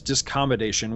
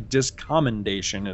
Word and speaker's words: discommendation: And of course, Michael Dorn, you know, with all respect to him, he mispronounces the word as discommodation, which he does, I think, for the whole rest of the series. Whenever he refers to it, it's actually discommendation is what it is.